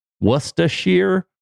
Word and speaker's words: Worcestershire: And then finally, Worcestershire sauce is not pronounced that way. You never pronounce the first R, apparently. It's either Worcestershire or Worcestershire.